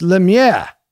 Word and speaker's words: Lemieux: No, he's disparaging you because you're not pronouncing the name of his neighbor correctly. It's Lemieux.